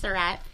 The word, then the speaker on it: Suratt: Sorry, I mispronounced your name. – Yes. – I was like, what did you call me? No. – I was like, I'll tell her in a second. It's Surratt.